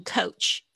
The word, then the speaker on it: coach: 像我们说老师啊、教练啊，可以用 coach 这个字。那大家特别注意一下，o a 的发音是 o 的声音，coach。